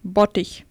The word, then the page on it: vat: german